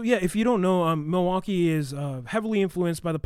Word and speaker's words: Milwaukee: If you hear it, the true pronunciation is Mwa-Kee.